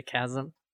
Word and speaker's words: chasm: Yeah, isn't a chasm? – It's a chasm. – Oh, is it a chasm? – Yeah. It's spelled like chasm. But it's chasm.